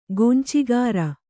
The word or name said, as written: ಗೊಂಚಿಗಾರ